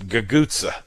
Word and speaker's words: cuzza: Yeah, my Gagutsa, which is spelled C-U-Z-Z-A. And pronounced in that, I guess, Sicilian or whichever dialect it is that's gotten to be very big around New Orleans, we usually pronounce it Gagutsa, as if it's two G's, sort of.